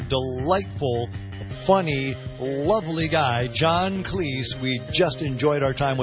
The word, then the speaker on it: Cleese: Hear the full interview with John Cleese. Rhymes with cheese. Cleese. Cheese.